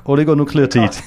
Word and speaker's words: Oligonukleotide: Der Begriff ist hier Oligonukleotide. – Sehr gut. Oligonukleotide.